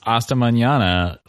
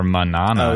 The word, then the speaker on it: Hasta Mañana: The only reason I feel like this song is worth talking about is just they actually pronounce Hasta Mañana like pretty well. And it's absolutely something that... a lot of white Americans in pop music at this time would not. Like, they would have probably said Hasta Mañana or Manana.